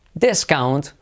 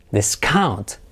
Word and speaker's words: discount: Como nombre, discount. Como verbo, discount. Yes, hay un descuento. Is there, esto tiene descuento. Is there a discount on this? Lo mismo, acentuamos la primera sílaba como nombre.